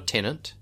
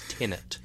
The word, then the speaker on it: Tenet: I think most people pronounce it with an N, but it's not a tenant. Christopher Nolan is not a landlord. It's Tenet.